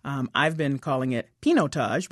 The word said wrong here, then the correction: Pinotage: because it looks like Pinot on the front end of it. But in fact, it's Pinotage.